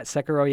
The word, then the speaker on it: Sekiro: It beats Sekiro. Is that how you say it? – Yeah, Sekiro, yeah.